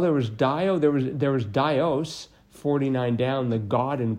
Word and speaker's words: Dios: Dios is pronounced.